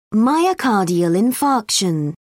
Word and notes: myocardial infarction: British English

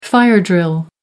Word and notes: fire drill: American English

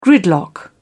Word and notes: gridlock: British English

